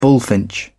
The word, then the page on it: bullfinch: British English